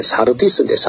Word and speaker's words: 사르디스: 4대 교회가 나오는데 그 4대에는 우리 할아버지들이 그저 지명을 4대 한 것이고 실제 우리가 발음 제대로 하면은 사르디스인데 사르디스.